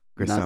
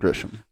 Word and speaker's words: Grissom: Grissom. Not Grisham. – We were both switching back and forth, and I never caught myself doing it during the episode. I never thought once, like, I'm saying this guy's name wrong.